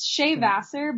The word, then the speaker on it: Shea Vassar: I said that right. I'm not... – It's Shea Vassar, but honestly, at this point in my life, you can say it however you want. – Oh, thank God. – Yeah, I'm not too picky. – Yep. – Does anyone mispronounce the Shea? – Yeah, I get Shea a lot.